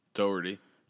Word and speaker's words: Dougherty: And then, But this other guy, so how do you pronounce this? D-O-U-G-H-E-R-T-Y. – Doherty. – Doherty? That's what I thought. But I wasn't sure because I've never actually seen Doherty.